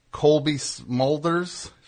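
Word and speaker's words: Cobie Smulders: It's a nice segue there, because it's you, it's you, Guy Pearce is in it, and Colby Smulders, is that how you say her name? – Yes, from How I Met Your Mother.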